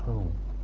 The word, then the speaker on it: Om: And then om, sacred word of the Hindus, doesn't work without the visualization. Om is composed of three syllables, A, U, and M.